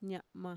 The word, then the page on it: ña'ma: Mixtec